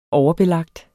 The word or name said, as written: overbelagt